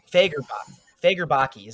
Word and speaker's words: Fagerbakke: I'm going to mispronounce this, Fagerbach? I think it's Fagerbach is how it's pronounced. – don't know. – Fagerbakke is what it is. Fagerbakke.